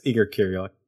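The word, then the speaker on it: Igor Kieryluk: It is beautiful, beautiful, haunting art from Igor Kirillik. It's like a little fuzzy on the card, so I'm probably pronouncing that incorrectly. – Yeah, it's Igor Kirillik.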